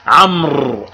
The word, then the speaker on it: عمرو: فعثمان بن عفان رضي الله عنه كان يسمى في الجاهلية أبا عمرو، أبا عمرو يعني مش عمر، عمر نكتب عين ميم راء بدون واو، وإذا أردنا أن نكتب عمرو نكتب عين ميم راء واو، فالواو تكتب ولا تلفظ.